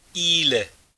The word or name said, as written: Ile